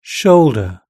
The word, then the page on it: shoulder: British English